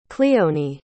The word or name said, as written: cleonie